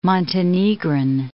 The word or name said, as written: Montenegrin